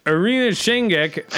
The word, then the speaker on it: Irina Shayk: Last week, Bradley Cooper and girlfriend Irina Shankish, Irina Shankek, I think you're saying it right. – Irina Shayk ended their relationship after 4 years of dating, according to People.